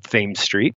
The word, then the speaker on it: Thames Street: And if you walk along Thames Street, so it's spelled like the River Thames in London, but because this is Baltimore, we call it Thames Street.